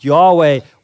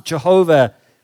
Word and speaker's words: Yahweh: You can pronounce it Yahweh, or you can pronounce it Jehovah.